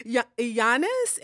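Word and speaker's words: Jannes: And I was pronouncing it Janice. – Janice and Jumbrace. I'm sorry. It's actually pronounced Janice and Iambres.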